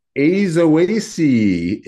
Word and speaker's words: Aizoaceae: Azoaceae, A-I-Z-O-A-C-E-A-E.